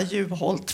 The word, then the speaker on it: Juholt: Och Håkan Juholt, det var fint att du uttalade Juholt. – Namnet rätt. Jag har aldrig lyckats lära mig det, så jag kommer nu redan efter att säga Juholt. För det ligger bättre i munnen på mig.